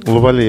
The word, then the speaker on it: Лавале: Лавале, я правильно сказал это слово? – Да, мне понравилось.